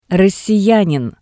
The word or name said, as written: Rossiyanin